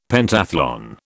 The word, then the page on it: Pentathlon: British English